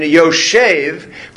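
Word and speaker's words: Yoshev (יושב): Yashav, which is another one of the examples the Torah does not have punctuation, which means that it's pronounced by tradition Yoshev, but if you were just reading it, it would be read Yoshav.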